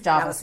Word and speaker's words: Jervis: Is it Jarvis? It is Jarvis, isn't it? – Yeah. – Why is that? Why do they spell it Jervis? – Anyway, it's Jarvis.